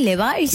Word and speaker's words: Levi's: El Levi's. ¿Se pronuncia así, Levi's? – Levi's. – Ah, yo pensé que era Levi's. Me compré unos Levi's. – Pero acá Levi's le decimos Levi's.